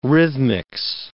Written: rhythmics

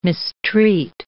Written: mistreat

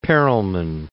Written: Perelman

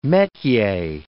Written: metier

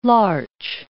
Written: larch

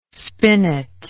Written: spinet

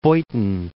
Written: Beuthen

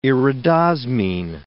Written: iridosmine

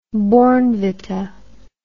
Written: bournvita

